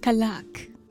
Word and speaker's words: Callac: So we're thinking that the pillar's name is Kallac, C-A-L-L-A-C, but he wants everyone to pronounce it with a kind of like French flair. Kallac.